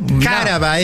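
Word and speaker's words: cáraba: Caraba, no. Es esdrújula, es con acento en la A.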